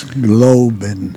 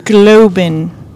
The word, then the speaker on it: Glauben: We're actually on location at the Dallas Holocaust Museum, and Max Gruber is with us today. Oh, I butchered his name. – Max Globen. – Globen. – G-L-A-U-B-E-N. – Okay. I am so sorry. – Believe. It's believe.